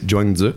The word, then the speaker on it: Zhuangzi: Oui, premièrement, la syllabe que tu avais eu de la misère, là, ça se prononce comme fudge, c'est Zhuangzi.